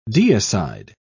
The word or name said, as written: deicide